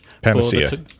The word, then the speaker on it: panacea: So they're not claiming to have created a panacea. Is that how you pronounce it in the English? – Panacea.